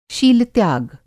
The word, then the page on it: शीलत्याग: शील-त्याग